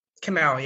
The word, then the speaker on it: Kimelle: Is it Kimelle? – Kimelle, yeah.